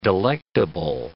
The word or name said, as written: delectable